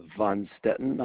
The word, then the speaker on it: Von Stetten: Yes, Alexis, my name is Wayne, and the last name is Von Stetten. I'll spell that for you. V as in Victor, O-N, capital S-T-E-T-T-E-N.